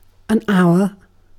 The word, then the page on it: hour: British English